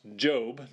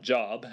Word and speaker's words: Job: Job is pronounced Job, not Job, as I first initially thought, and I know many other people have as well.